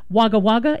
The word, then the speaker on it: Wagga Wagga: Wagga Wagga, is that how it's pronounced? Wagga Wagga? Oh, you weren't pronouncing it incorrectly.